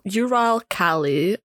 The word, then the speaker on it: Uralkali: Uracali. Let's just call it that. Uracali. – You said it's so like Southern American. Uracali. Uralkali. Yeah, the Russian petrochemical giant.